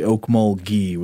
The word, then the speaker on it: Okmulgee: I think I said Okmulgee. It's apparently Okmulgee with a hard G.